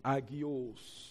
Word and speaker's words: hagios: Agios. Pronounced agios.